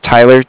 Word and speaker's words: Tyler: Okay, yeah, my name is Tyler, T-Y-L-E-R, and like I said, I was calling with Cardiff, it's spelled C-A-R-D-I-F-F, as in Frank.